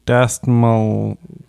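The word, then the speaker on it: Dastmalchian: David Desmalchian.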